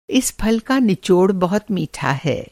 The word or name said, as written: निचोड़